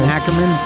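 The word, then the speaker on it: Akerman: Malin Ackerman.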